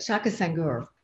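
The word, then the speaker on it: Shaka Senghor: Shaq Sangura, did I get his name right? He knows that he teases me because I'm always getting his name wrong. But Shaka Sangur,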